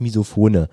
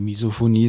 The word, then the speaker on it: Misophonie: Ich dachte, oder heißt das Misophone? – Nee, Misophonie ist schon...